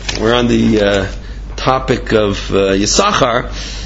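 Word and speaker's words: Yisachar (יִשָּׂשכָר): We don't say Yisachar. We call it Yisachar as if there's only one. One of the Sins are silent.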